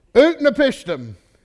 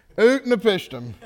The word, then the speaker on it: Utnapishtim: Utnapishtim. Utnapishtim.